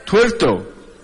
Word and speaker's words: tuerto: Y en el país de los ciegos el tuerco es tuerco. Tuerco. Tuerto. Con T.